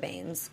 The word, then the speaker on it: Banes: Continuing on into episode 20, which was Twigs and Twine and Tasha Baines.